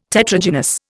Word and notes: Tetragynous: Indian

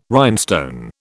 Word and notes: rhinestone: British English

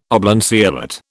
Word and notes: oblanceolate: British English

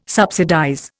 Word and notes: subsidize: Indian

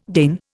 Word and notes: deign: Indian

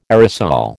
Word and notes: aerosol: American English

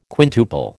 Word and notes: quintuple: American English

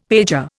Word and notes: pager: Indian

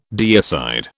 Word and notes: deicide: British English

